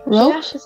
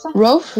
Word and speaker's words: Rough: R-O-U-G-H, je sais pas prononcer cette chose. Autant le roux, je peux, autant le G-H, non, ça m'inspire pas là. J'ai pas de syllabe qui vient avec ça. – R-O-U-G-H.